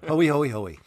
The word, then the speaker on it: Hoey: We were debating how to pronounce Dennis Hoey's name, even though it's in the recording. We didn't bother to pay any attention to it. – I'm sure it's a Dennis Hoey.